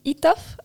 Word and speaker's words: Etaf: ma räägin sellest, mida mul kaasas ei ole esimesena, et ähm, ma lugesin Etaf Rumi, eh, siis Naine pole mees, ma loodan, et ma hääldasin autori nime õigesti, ma vaatasin, et ingliskeelses meedias on küll öeldud ka ITAF, aga lähtusin eesti keele hääldusest.